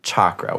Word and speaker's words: chakra: Chakra with a hard C. – So like chalk.